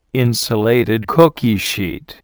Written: insulated cookie sheet

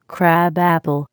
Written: crab apple

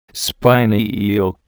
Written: spiny eel

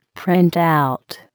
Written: printout

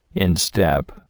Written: instep

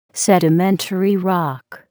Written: sedimentary rock

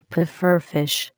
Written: pufferfish

